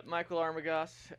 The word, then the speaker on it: Michael Armagost: Yeah, Michael Armagost, a.k.a.